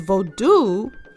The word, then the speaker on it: vodou: So for example, you may see, even though it's pronounced phonetically as vodou, it's spelled differently. So the French spellings, there is V-O-U-D-O-U. There's also vodou, which is V-A-U-D-O-U.